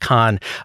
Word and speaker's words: Cannes: Worth noting, by the way, that Anatomy did win at, and I appreciate that you're saying the proper French pronunciation of Cannes.